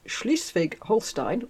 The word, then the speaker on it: Schleswig-Holstein: Because John's failed bid to take over Schleswig-Holstein, and I did look up the pronunciation for that as well,